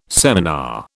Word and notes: seminar: British English

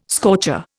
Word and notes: scorcher: Indian

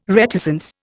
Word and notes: reticence: Indian

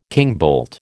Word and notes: kingbolt: American English

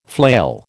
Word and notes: flail: American English

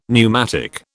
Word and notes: pneumatic: British English

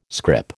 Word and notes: scrip: American English

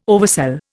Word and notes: oversell: Indian

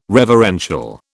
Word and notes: reverential: British English

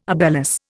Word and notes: obelus: Indian

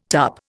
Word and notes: tup: Indian

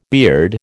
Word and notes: beard: American English

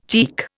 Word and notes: cheek: Indian

